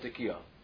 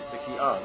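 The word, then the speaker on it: Tekiah: The word is Tekiyah. Yeah. Not Tekiyah. The pronunciation is Tekiyah.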